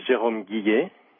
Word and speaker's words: Jerome Guillet: Am I pronouncing your name correctly? – It's a hard name to pronounce in English, but you did well. – Do you want to say it so I can do it a little better? – My name is Jerome Guillet.